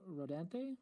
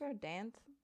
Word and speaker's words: Rodanthe: *Nights o in Rodante, is that how you pronounce that? I think Rodanthe.